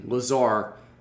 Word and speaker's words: Lazar: Yeah, take off the B-I and add a L-A, you got the pronunciation. – Lazar.